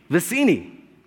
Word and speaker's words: Vizzini: Vizzini.